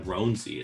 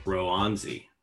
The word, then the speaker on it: Roanzi: It's not even Ronzi, it's like Roanzi. It's what the Altoona like media guide says.